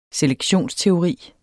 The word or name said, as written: selektionsteori